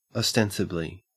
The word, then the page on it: ostensibly: Australian English